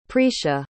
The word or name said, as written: precia